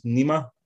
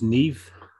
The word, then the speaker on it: Niamh: You've got, in the film, you've got the lead, I think it's Nima. It's spelled N-I-A-M-H. – That's Neve. – Neve, is it? That's like Saoirse Ronan's not spelled N, it's Irish, it's Neve.